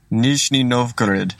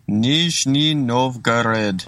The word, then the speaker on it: Nizhny Novgorod: We're going to establish a new club in a new place," and this is how you say it. – Nizhny Novgorod. – Okay, in Nizhny Novgorod. Is that right? – Nizhny Novgorod. – All right. Doesn't he sound a bit pissed off there? He's like, "It's Nizhny Novgorod, for goodness sake."